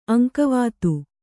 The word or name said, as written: ಅಂಕವಾತು